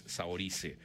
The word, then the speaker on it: Saoirse: Saoirse, creo que es así se debe pronunciar en irlandés. Saoirse Ronan, creo que es Saoirse.